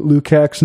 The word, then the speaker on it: Lukács: It's Lukács, not to be confused with George Lucas.